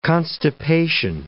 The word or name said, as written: constipation